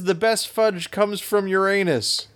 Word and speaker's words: Uranus: Uranus, is that, Missouri, Ben? – Is that how it is pronounced, sir? – Yes.